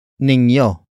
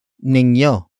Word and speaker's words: ninyo: The next word is ninyo. Ninyo. Ninyo. Ninyo is spelled as N-I-N-Y-O.